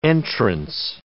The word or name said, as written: entrance